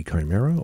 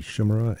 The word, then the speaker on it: Chimera: And Chimera, or Chimera, depending upon whether you like your pronunciation of this mythical creature, comprised of three different animals, at least as you like your pronunciation. Greek, which would be Chimera, or Latin would be Chimera.